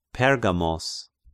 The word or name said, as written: Pergamos